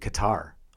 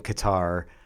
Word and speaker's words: Qatar: They had a guy looking in a Western baseball hat, but he described how to say it, and there are three letters in Qatar that have no corresponding sound in English. So when you hear him say it, it's amazing. The R is rolled. And the k sound comes from a different part of your throat than what we use.